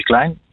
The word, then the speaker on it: Kleyn: Eh, mijn naam spreek je uit als Le Klein. – Le Klein. En ook een oh. beetje Duits. En ook een beetje Duits. Ja, het, is wel een het, blijft, het blijft zeker, zeker hangen.